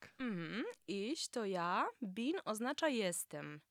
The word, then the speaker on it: ich: Jak zauważyłeś, ch w zaimku osobowym ich jest wymawiane podobnie do polskiego si. Ale nie do końca. Najlepiej poćwiczyć ten dźwięk wymawiając słowo chinina. – Chinina. – Wtedy ten dźwięk jest pomiędzy eś a ch.